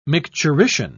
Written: micturition

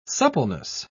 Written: suppleness

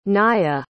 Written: naya

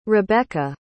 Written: rebekah

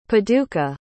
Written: paducah

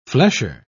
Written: flesher

